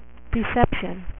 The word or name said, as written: Decepticon